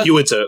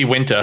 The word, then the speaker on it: Uinta: Is it Yunta Brewing Company? Is that how you pronounce it? – I think it's Uinta. – Uinta. Okay. – It might be Winta.